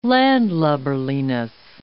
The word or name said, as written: landlubberliness